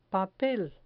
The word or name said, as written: papel